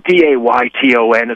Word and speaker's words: Dayton: Yeah, the last name's Dayton. It's spelled Dayton. It's like the boot, the town, or the tire.